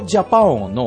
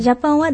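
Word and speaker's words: Japão: そうですね、すべてほとんどの言葉は定冠詞がつきますので、うん、ジャパンは男性名詞です。なので、うん、おがつきます。 – ジャパン、 J-A-P-A-O と書いて P-A-O の A の上にニョロがつくんですね。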